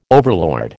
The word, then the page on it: overlord: American English